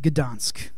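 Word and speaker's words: Gdańsk: And if I can say it right, Gdansk. Yes, okay, I said it right.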